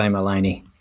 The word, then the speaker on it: Maleny: And Lyndell came to us from how do you say it? Maleny Trail? – I'm going to say Maleny, yes.